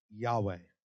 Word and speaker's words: Yahweh: In fact, it's actually translated or spelled YHWH. In Hebrew, they don't actually have vowels. It's only consonants. So it'd be YHWH, or in some translations, it's YHVH. So, but it's pronounced Yahweh.